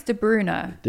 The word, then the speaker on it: De Bruyne: How do you say his name? – Apparently it's De Bruyne.